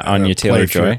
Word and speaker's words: Anya Taylor-Joy: I think the really thing I'm excited about is seeing, I'm going to butcher this name, Anya Taylor-Joy. – Yeah, Anya Taylor-Joy.